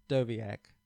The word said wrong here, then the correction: Dijakovic: or whatever. I can't. – Dijakovic. – You even told me that. I still said it wrong. Dijakovic.